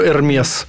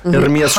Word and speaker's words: Hermès: Ну, мы знаем, что на самом деле правильно Hermès. Hermès, how to pronounce Hermès.